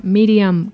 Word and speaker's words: Miriam: que sería Miriam con M de Madrid y dos I latinas normales, que es un nombre que a veces se escribe diferente.